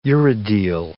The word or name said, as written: urodele